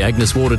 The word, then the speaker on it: Agnes Water: Heading down to Agnes Waters. – Mate, it's pronounced Agnes Water.